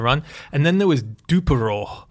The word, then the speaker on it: DUPAROOH: Dupero, D-U-P-A-R-O-O-H. – I think it's more like Dupero, you know. – Oh, Dupero. – Yeah. Like Dupero, like Winnie the Pooh. Dupero.